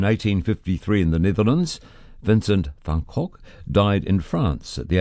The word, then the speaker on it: Van Gogh: And that's from the Dutch post-impressionist painter Vincent van Gogh.